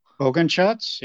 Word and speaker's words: Bogenschutz: So, so I don't butcher your last name today. We are talking with Todd. Is it Bogenschutz? – Bogenschutz, yep. Good wow.